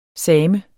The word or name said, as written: SAMEN